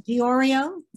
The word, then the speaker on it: Diorio: Yes, it's Diorio, spelled like the designer and pronounced like the cookie. It's a D-I-O-R-I-O, Diorio.